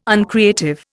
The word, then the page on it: uncreative: Indian